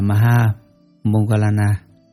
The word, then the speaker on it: Maha Moggallana: Ngài Maha Mục Kiền Liên, Ấn Độ đọc là Maha Moggallana, chữ Hán là Đại Thái Thúc Thị, là bậc có phép thần thông đệ nhất.